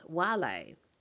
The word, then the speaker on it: vale: Well, what I learnt anyway was because a lot of people will say sign because it's spelled that way, just as a lot of people say veil when they are writing, you know, after someone passes. – Yeah. Right? – But it's vale. – Oh, vale, not vale. – Yeah, so the V's are actually pronounced W.